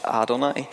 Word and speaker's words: Adonai: which is pronounced Adonai, and effectively how that morphed in English was that it effectively became Yehovah.